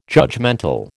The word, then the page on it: judgemental: American English